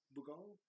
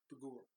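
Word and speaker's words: Bughuul: Bugal? – No. – Bugul. – Bugul. There Bugul. You go. I was like, why can't I remember how to say it now? Yeah, it's Bugul.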